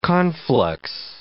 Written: conflux

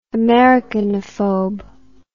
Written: americanophobe

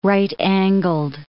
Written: right-angled

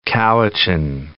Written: North Cowichan

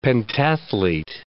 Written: pentathlete

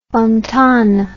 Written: Fontane

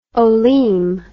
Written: olim

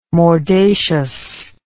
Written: mordacious

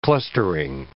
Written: clustering

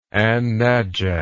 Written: an najaf

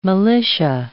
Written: militia

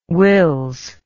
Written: wills

